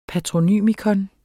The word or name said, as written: patronym